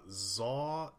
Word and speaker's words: Zawe: Uh, Zawe Ashton.